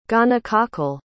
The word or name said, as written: gonococcal